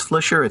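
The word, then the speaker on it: Flisher: My name is Chris Flischer. It's F-L-I-S-H-E-R.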